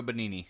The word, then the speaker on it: Benigni: I also suggest if you want to cry your fucking balls off, I suggest you watch Life is Beautiful by Roberto Benigni. Is that how you pronounce his name? – Yep, Roberto Benigni.